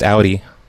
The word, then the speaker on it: Audi: Okay, it's an Audi. It's pronounced Audi. – Well, I pronounce it Audi because I'm from New York.